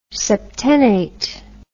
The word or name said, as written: septennate